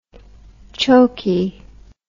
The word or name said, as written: chokey